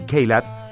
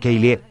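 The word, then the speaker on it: Caillat: Jason Russ y Colby Kaylat, o Kaylee, porque así se pronuncia, también le cantaron a la suerte.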